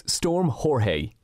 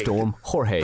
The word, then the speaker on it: Jorge: And if you're probably going to be stuck in over the weekend because the weather from Storm Jorge, is that the correct pronunciation? I think Jorge. We actually got on to the guy, one of the guys who does the weather over in Network 2, and we asked him to give us the correct pronunciation. – Hey guys, so it's Storm Jorge. – Jorge. – Storm Jorge. – Thanks very much.